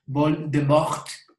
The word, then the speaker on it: Voldemort: Española, no, o sea, eh, es de Voldemort es una palabra en francés, Voldemort, perdón, en mi traducción, mi pronunciación, pero quiere decir vuelo de muerte.